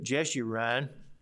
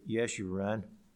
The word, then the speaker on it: Yeshurun: But Jeshurun, or as it is in Hebrew, Yeshurun, or Yeshurun, I believe would be a better pronunciation, which comes from the root word Yashar, which means upright.